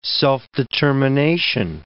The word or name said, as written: self-determination